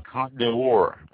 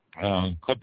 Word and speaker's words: Côte: The Côte d'Or, I think it's pronounced Côte, C-O-T-E, with a little upside-down V over the O, whatever that thing's called.